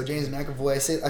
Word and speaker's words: James McAvoy: James McAvoy, you got it right, okay. Yeah? So James McAvoy, I felt like people, when they saw him, they were like, whoa, this is a different take.